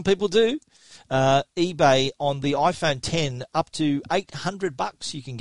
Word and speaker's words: iPhone X: iPhone X is otherwise known as the iPhone 10, however you want to pronounce that,